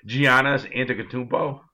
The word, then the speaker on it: Giannis Antetokounmpo: Giannis Antetokounmpo.